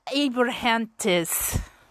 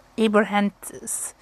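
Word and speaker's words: Abrahantes: I'm butchering his last name. I'm sorry. I do follow him on Instagram. Wait, let's solve this. Let me make sure I say this right, because I'm trying to remember. Yeah, it's Alex Abrahantis. Abrahantis. Yeah.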